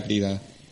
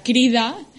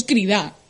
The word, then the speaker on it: crida: Las personas que mm, pronuncian bien la palabra crida y luego las que la dicen mal, que yo estoy dentro de ese saco y decimos crida. – Ah, no se dice crida? Está mal dicho.